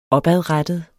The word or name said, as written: opadrettet